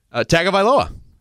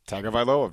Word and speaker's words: Tagovailoa: Tagovailoa. – Tagovailoa, very good.